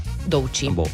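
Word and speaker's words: Doechii: Doici.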